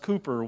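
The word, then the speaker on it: Cowper: That's C-O-W-P-E-R, pronounced Cooper, William Cooper.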